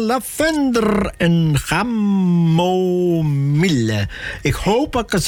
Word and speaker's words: chamomile: Chamomile of zo. Dus ik heb hem opzacht nou, en dan moest het zo uitspreken. Sure. – Chamomile. – Chamomile.